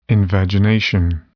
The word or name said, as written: invagination